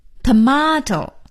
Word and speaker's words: tomato: T-O-M-A-T-O, tomato, 西红柿 tomato. 美音的发音呢是念成 tomato, T-O-M-A-T-O, tomato. 所以 tomato 和 tomato 都是对的。